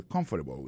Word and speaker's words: comfortable: Accent tomber sous premier syllabe. I don't feel comfortable with that.